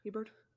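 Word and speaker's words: Hebard: You got Candace Parker, Ezra Stevens, Ruthie Hibbert, and why? I feel like I mispronounced her name. – Hebert. – Whatever. – Hebert. – Hebert.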